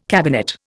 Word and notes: cabinet: Indian

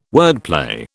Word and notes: wordplay: British English

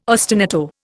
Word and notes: ostinato: Indian

